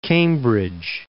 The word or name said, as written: Cambridge